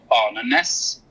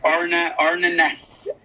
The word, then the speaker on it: Arne Næss: And I'm particularly inspired by a Norwegian philosopher called Arne Næss, who is considered one of the founding fathers of eco-philosophy.